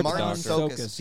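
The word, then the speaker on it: Marton Csokas: I don't even know how to pronounce his name. – Oh, Martons. – Oh, the doctor? – Sokas? – Yeah, Martons Sokas. Yeah.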